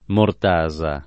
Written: mortasa

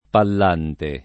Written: Pallante

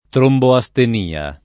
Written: tromboastenia

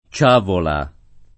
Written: Ciagola

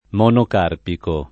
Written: monocarpico

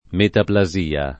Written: metaplasia

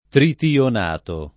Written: tritionato

